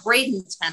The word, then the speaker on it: Bradenton: Bradenton, I think. Mm-hmm. I think I'm pronouncing that right. – Yes.